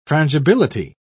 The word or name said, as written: frangibility